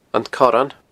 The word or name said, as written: Corran